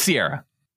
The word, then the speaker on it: Ciara: Well, which one is it? Is it Sierra or Ciara? – It's Sierra. – Like the mountains? – Yes.